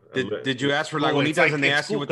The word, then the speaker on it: Lagunitas: That's how it's pronounced. Lagunitas. – Lagunitas. – Are you serious? Real? I've been saying it wrong this whole time. – Lagunita? – Yeah, it's because you're in Miami, so you just figure, oh, eso es Lagunita. But no, it's Lagunitas.